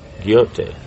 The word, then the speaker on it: Goethe: Goethe. Not a goat. Did he have that little goatee? So they just called as you see him. Okay, he looks like a goat. So Goethe.